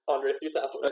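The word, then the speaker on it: André 3000: No, is it, you said it correctly, Andre 3000.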